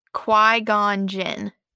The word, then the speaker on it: Qui-Gon Jinn: Qui-Gon Jinn.